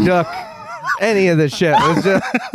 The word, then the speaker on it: Babadook: Did you guys see the Babadook? – Yeah, I know. – It has to rhyme with book.